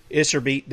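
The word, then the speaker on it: Iserbyt: in our shows that we did with Charlotte Isserbeat, did I say that correctly? – Yes, you did.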